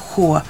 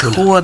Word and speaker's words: Хула: Това, което потриса целия свят, но се оказва недостатъчно за Сирия, едно клане в Хула, така ли ударението? Хула. Град Хула, да.